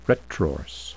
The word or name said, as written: retrorse